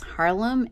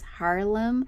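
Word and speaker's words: Haarlem: I'm I don't know how you pronounce this Harlem H A A. There are two A's. Harlem. – Harlem.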